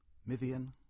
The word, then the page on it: Mithian: English